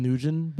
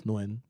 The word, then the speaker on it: Nguyen: I used to think, like, it was Nugent, but it's Nguyen.